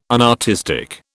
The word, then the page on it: unartistic: British English